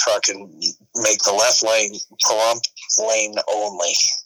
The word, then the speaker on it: Pahrump: to Pahrump.